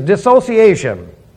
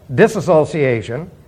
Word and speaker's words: dissociation: The third one, it is dissociation. And dissociation, sometimes mispronounced, disassociation. Dissociation. You want me to spell it for you? Good. D-I-S-S. O-C-I-A-T-I-O-N, dissociation.